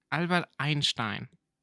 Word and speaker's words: Einstein: Albert Einstein.